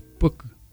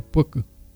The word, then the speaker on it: puiki: La palabra es puk, se escribe como puiki, pero se pronuncia puk.